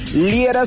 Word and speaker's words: Liera: Si quieres sugerir algún punto para esta semana, bien sencillo, entra a mis redes sociales, búscame por mi nombre, Fernando Liera, se escribe L-I-E-R-A, y el día de mañana o en estos días estaremos por allá.